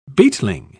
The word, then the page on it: beetling: British English